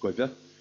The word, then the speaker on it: guidhe: Now, this you get this in I suppose sporadically in modern dialects, such as the word which is traditionally spelled G-U-I-D-H-E, is in certain dialects in Connacht pronounced Guivhe. The word for ivy, which is traditionally spelled E-I-D-H-E-A-N-N, is in some dialects pronounced Evan.